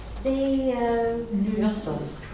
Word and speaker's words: Theopneustos: Yes, I'm not sure whether you pronounce the P or not, whether it's Theopneustos or... – It goes together like pneumonia. – That's right, well, it's a silent P when we say... – Yeah, but that's only true at the beginning of the word.